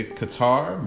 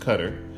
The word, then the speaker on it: Qatar: I know a lot of people say Qatar, but it is actually pronounced Qatar.